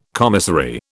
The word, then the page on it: commissary: British English